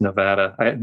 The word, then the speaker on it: Nevada: It's like Nevada is not Nevada. A lot of people want to say Nevada, but they get bristled over that. It's Nevada.